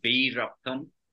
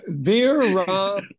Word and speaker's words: Veerappan: It's Veerappan. – Okay. Veerappan.